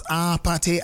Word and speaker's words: APT.: Apate, apate, APT. That's how they pronounce it in the song.